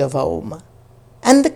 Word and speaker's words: Om: And whereas the cows could do the absolute brilliant Sandhi sound of Om, that's A, U, and Ma, Kara sound put together in Sanskrit, that becomes a Sandhi that is then pronounced Om,